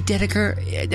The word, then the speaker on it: Dedeker: Hey, Dedeker, am I saying that correctly? – Yes, 100%. You got it on the first try.